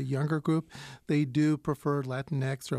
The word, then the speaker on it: Latinx: I did say Latinx.